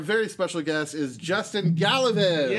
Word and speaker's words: Galavis: It's Galavis. – Galavis. – So, gal like a woman, law like you know, like you're going to sing a song, yeah. – And V's like two V's.